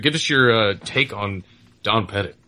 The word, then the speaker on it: Pettit: Is it Petit or Petit? It's Pettit, right? Is that how he pronounces it? – It's Pettit, yes.